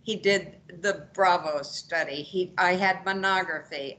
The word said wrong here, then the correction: manometry: I had, am I saying that right, Dr. Forcione? – Monography? Manometry. Manometry. Manometry. Yeah.